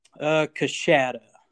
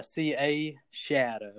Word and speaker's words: Coushatta: Coushatta. – Coushatta. – Coushatta. Yeah, C-A-shatta, but it's spelled C-O-U-shatta.